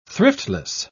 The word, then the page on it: thriftless: British English